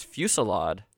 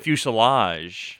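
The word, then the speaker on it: fusillade: Hit me with first one is fuselade. – Ooh. – Not fuselage. – No, not fuselage on an airplane. Fuselade. I believe it's French.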